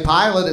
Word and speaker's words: Pilate: So, but if you didn't know, if you hadn't seen it written, it sounds like when you say pilot, it sounds like airline pilot. So you might think it's spelled P-I-L-O-T.